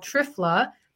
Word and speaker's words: Triphala: The second supplement is also for all three dosha, and it's called Trifla. That's spelled Triphala.